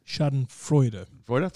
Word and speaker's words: Schadenfreude: Schadenfreude.